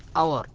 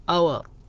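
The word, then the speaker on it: hour: وقلنا يا اخوان يا اخوات ان الكلمة التي تبدأ بحرف ساكن في النطق، يعني انت ايش نطقت مثلا كلمة ساعة اللي هي اور، شوف هنا الاتش فعلا حرف ساكن المفروض ان احنا نضع فيه اي، هنا ما وضعنا فيه اي، خالف القاعدة. السبب لان نطق اللي هي الاور نطقها بالاو، آ اور، فلذلك وضعنا هنا ان اور، السبب لانها لم تبدأ في النطق بحرف ساكن.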